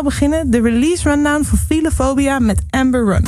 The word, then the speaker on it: Philophobia: Philophobia. Philophobia.